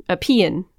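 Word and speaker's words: paeon: So a Payon, that's how it's pronounced apparently, Payon.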